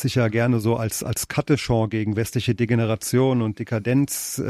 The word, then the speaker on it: Katechon: Der Katechon des Björn Höcke ist eben richtig ausgesprochen der Katechon.